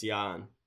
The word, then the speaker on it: Ian: Ian Mahimi of the Wizards. – I believe it's Jan. Mm. We're not good at pronouncing. – That's Jan Veasley. – And it's Jan Mahimi.